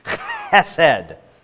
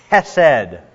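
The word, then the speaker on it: hesed (חסד): The Hebrew word is hesed, or sometimes spelled with a K in front of it, to try to get across the fact that the English reader is supposed to go something like hesed. All right. Now, if you don't spit on the person in front of you when you say the word, you're saying it wrong.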